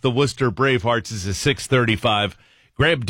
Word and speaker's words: Worcester: And then in bright yellow lettering at the bottom of the script here, it says, pronouncer, Worcester is pronounced Worcester.